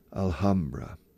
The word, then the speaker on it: Alhambra: In English, para decir la Alhambra de Granada, recomiendo aspirar la H, Alhambra. Because if you say Alhambra, native English speakers perhaps will not understand you. See, the Alhambra in Granada. Ah, the Alhambra. Because we pronounce the H in this case.